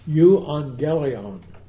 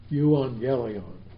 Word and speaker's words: Euangelion: let me pronounce the word gospel for you. Euangelion. Euangelion.